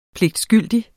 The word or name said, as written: pligtskyldig